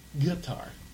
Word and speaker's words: Qatar: The actual proper pronunciation of Qatar is Qatar, Qatar. It's like a... – Qatar? – Like, yeah, think about like a guitar, but it's Qatar. – Qatar, okay. – Yeah. So that's the proper pronunciation.